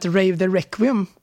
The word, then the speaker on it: Rave the Requiem: Och som den här jingeln då lite blygsamt skvallrar om, så har vi nu fått in Petter och Filip från rockbandet Rave the Requiem. Uttalade rätt? – Ja då, det låter jättebra.